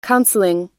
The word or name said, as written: counseling